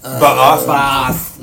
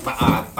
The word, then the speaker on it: Ba'ath: I looked it up. It kind of is pronounced like that. – Ba'ath. – It's like Ba'ath. – Well, anytime you got the dual A, it's separated.